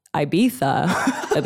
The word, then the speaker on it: Ibiza: For everyone's going to destroy me for saying it that way. I went to Ibiza. That's how they say it in England. And if you say Ibiza, they're like, it's Ibiza. And when you go to Ibiza, they say it's Ibiza.